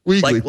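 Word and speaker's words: Wiegley: Wiggly. – Like the grocery store, like the rabbit in the storybooks.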